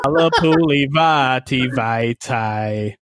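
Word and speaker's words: Halapoulivaati Vaitai: The fact that you can say his name to play that funky music, white boy, made it incredibly easy. – Oh, no. Oh, no. – Halepuli Vaativaitai.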